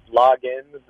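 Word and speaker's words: Loggains: I usually just call him Dow, but from what I can understand, it's Logans, like Kenny Logans.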